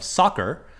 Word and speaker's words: soccer: Now, the sock part of soccer is actually an abbreviation for association, the S-O-C part of the word association, with a hard C when you turn it into a word soccer.